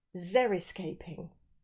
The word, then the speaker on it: xeriscaping: And I learned a brand new word when I was doing my research, and it's called Xeriscaping, which I think I've said it right. It's X-E-R-I scaping.